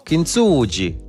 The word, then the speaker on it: Kintsugi: No, Kintsugi.